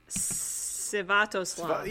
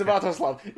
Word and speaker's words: Sviatoslav: Sviatoslav. – Yeah, oh, okay. Sviatoslav.